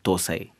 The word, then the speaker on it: dosai: For example, we call it dosai, right? So we pronounce it with the, you know, sound D. When it comes here, it's more like tha, dosai.